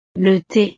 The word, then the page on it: le the: frances